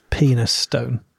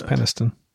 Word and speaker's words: Penistone: But it's spelled penis stone. Yes. But I think it's Penistone.